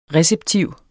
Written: RECEPTIVT

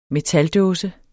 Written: metaldåse

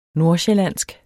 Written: nordsjællandsk

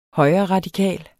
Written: højreradikal

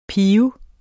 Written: piber

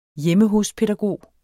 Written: hjemme

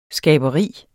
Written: skaberi

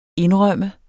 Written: indrømme

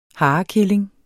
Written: harekilling

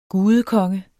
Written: gudekonge

